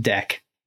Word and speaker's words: deque: And it's like a deck DEQUE is a double-ended queue, very useful data type for certain purposes, but people would type it deck. Like deck as in DECK.